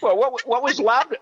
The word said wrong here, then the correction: laudanum: What Loudon? Is that how you say it? – Laudanum. Laudanum.